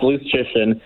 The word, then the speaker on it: Luszczyszyn: I feel like you did better last time. I think you fell apart there. It's Luszczyszyn.